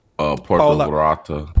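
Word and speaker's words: Puerto Vallarta: how do you say Polo Verata? How do you say that? – Puerto Verata.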